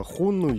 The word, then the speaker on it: хунну: Говорим о племенах э, хунну или сюнну, ежели использовать да, китайское э, произношение.